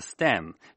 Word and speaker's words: STEM: STEM 念成是 STEM，这四个字母呢是科学技术工程和数学的英语缩写。最近一座微型城市模型刚刚获得了年度未来城市 STEM 竞赛大奖，请听美国之音记者皮米恩塔的报道。